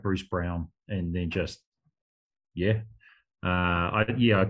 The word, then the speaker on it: Braun: Even, like, their rookie, Christian, well, it's spelt Braun, but it's pronounced Brown, apparently.